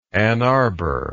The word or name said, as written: ann arbor